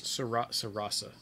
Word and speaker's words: Sarasa: Sarasa. – I'm sorry, Sarasa. I guess autocorrect changed. – They're both three syllables. Sarasa. You just change the middle and end ones.